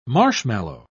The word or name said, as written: marshmallow